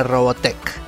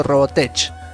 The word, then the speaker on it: Robotech: Al menos ya sé que si se me aparece el gaucho oscuro, le tengo que rezar a Robotech.